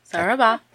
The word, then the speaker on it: Sarabá: Sarabá. – Sarabá. – Accent on the end.